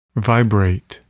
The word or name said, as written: vibrate